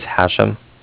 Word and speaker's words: Hashem: My name is Hashem. That's H-A-S-H-E-M, as in mother at the end. – Okay. – And I'm calling from Cardiff, and that's spelled C-A-R-D-I-F-F, like Frank at the end.